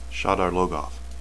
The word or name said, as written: shadar